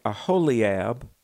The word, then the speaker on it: Aholiab: And he hath put in his heart that he may teach, both he and Aholiab, if I'm pronouncing that correctly. – Yeah, that's good. I think that's exactly right.